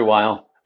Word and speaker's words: Weil: And the fellowship through the Andrew, is it Weil? Is that how you say his name? – Andrew Weil.